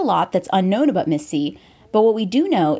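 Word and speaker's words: MIS-C: And you may have heard of a new condition that's been labeled MISC, pronounced Miss C, or multisystem inflammatory syndrome in children.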